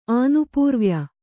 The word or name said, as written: ಆನುಪೂರ್ವ್ಯ